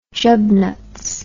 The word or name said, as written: jubnuts